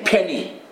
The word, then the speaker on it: Penney: Penny. How do you say? Penny.